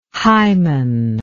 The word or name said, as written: hyman